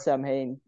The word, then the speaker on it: Samhain: You write it as Samhain, but you say Samhain.